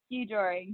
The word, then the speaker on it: Skijoring: Yeah, Skijoring.